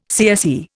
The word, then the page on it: CSE: Indian